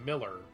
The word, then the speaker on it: Millar: But does she really think that Frank Miller is Mark Millar? – Well, it's actually pronounced Miller, so...